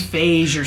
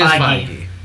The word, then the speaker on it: Feige: I always thought it was Feige or something. – They will fucking kill you with that pronunciation. – It is Feige.